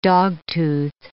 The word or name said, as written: dogtooth